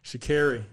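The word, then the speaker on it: Sha'Carri: Had to be a Shakari. Excuse me. Let's not pronounce her name wrong. – Shakari, man.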